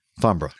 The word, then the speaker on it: Farnborough: Fambra. – Fambra.